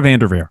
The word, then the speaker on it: VanDerveer: I never heard the name Tara Van Dierver before either. – I screwed up both names, Coach K and Vanderveer. There we go, Tara Vanderveer.